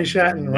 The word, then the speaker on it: Shatan: And you said, rhymes with Manhattan. – Jeremy Shatton.